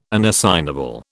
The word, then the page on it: unassignable: British English